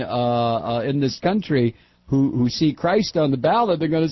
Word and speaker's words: Crist: And one's name is Charlie, C-R-I-S-T. – Charlie Christ? – Christ? It's pronounced Christ, but on paper.